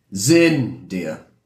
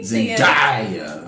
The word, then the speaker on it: Zendaya: Zendaya? Am I saying it right? Where do you think you put the intonation on that name? – Zendaya. – Zendaya. Yeah, the A. – Zendaya.